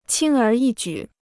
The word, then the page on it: 轻而易举: qīng ér yì jǔ